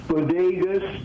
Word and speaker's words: Bodegas: an outfit called Bodegos Manzanos. Voce Bodegos Manzanos. Bodegas, B-O-D-E-G-A-S, capital M-A-N-Z-A-N-O-S.